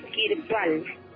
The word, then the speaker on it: Kirpal: Apellido debe ser S-I-G-H-N. Y el otro es J-I. Tawan Sis-I. Y el otro maestro es Kirpal. K-I-R-P-A-L.